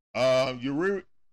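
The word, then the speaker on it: Urías: Uriel. Yeah, that's how you say it. Uriel against Max Fried.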